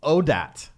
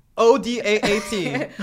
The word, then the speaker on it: ODAT: Hashtag renew ODAT. That's how it's said. – Yeah. – I was saying the whole thing when I was talking. I was like, renew ODAAT. – Like One Day at a Time.